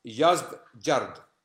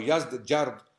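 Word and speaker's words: Yazdegerd: when the emperor was Yazdgard, or Yazdgard, depending on how you want to pronounce it. Literally, his name meant made by God. Yazdgard means made by God. Yazdgard or Yazdjard was the emperor of Persia who was related to Khusro II, who had ripped the letter of the Prophet ﷺ apart.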